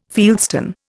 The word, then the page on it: fieldstone: Indian